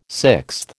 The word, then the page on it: sixth: American English